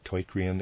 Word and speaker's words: teucrium: I'm pronouncing that teucrium, it's spelled T-E-U-C-R-I-U-M, end of readers aside.